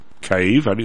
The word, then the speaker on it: Kyiv: Zelensky chatted about how he wanted to drain the swamp in Kyiv. Is that how you pronounce that? K-Y-I-V?